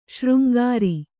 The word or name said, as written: ಶೃಂಗಾರಿ